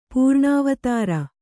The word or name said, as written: ಪೂರ್ಣಾವತಾರ